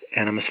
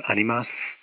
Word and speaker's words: Animas: And Animus is the Spanish word for souls, better pronounced animas. It's A-N-I-M-A-S. I'm emphasizing the A there before the final S because some people think it's the Jungian term animus, but it's not. It's the Spanish term for souls, A-N-I-M-A-S.